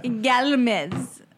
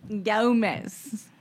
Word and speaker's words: Gomez: Galmez. Gomez.